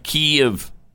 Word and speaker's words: Kyiv: I'm not going to call it that because that's not even correct. It's like Kyiv. – Kyiv. – Kyiv. You got to say it's like it's still two syllables.